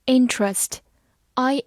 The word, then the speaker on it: interest: interest，i n t e r e s t，interest，i n 发 in，t e r 中的 e 发音省略，所以 t r 读作 tr。